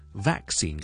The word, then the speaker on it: vaccine: 就系咧同 COVID 有关嘅一个英文啊，咁我第一个讲嘅字咧就系我哋成日都讲噶啦，vaccine 就系咧疫苗啊，vaccine 疫苗呢个字嘅发音咧系其实都几值得讲嘅，vaccine 嘅 vac 咧我系有个 a 嘅 vowel 嘅 vac，跟住后面咧我有个 k 音喺度，个轻轻嘅 k 音，ok，但系唔使太大声出嚟嘅 vac，跟住 vaccine，而后面嗰个 vowel 咧系个 e 音嚟嘅，所以系 vaccine。